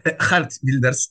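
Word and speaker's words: Geert Wilders: Kim jest Gerd Wilders? – Hart Builders. Hart, dobrze. – E, tak, tak, bo H i G jest bardzo, bardzo oryginalnie wymawiane przez, przez, przez Holendrów.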